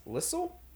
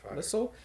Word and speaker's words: Liesl: Um, I don't know how to say this girl's first name, but i it seems like it's Liss Lissel. Lissel, mm-hmm. L I E S L, Lissel Michelle.